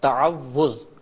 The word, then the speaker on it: تعوذ: صحیح لفظ ہے تعوذ،